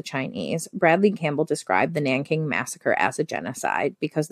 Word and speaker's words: Nanking: The corpses and massacred victims, with the Japanese soldiers standing nearby Nanking, I know how to say that. It's a J, but it sounds like a K. Nanking,